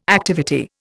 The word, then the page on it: activity: Indian